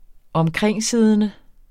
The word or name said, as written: omkringsiddende